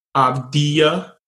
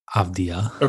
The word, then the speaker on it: Avdija: It is Denny of Avdija. – Avdija.